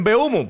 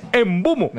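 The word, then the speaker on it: Mbeumo: que le decimos en Beumo, pues la gente joven va a decir, pero no saben Diego, cómo se pronuncia porque es Embumo.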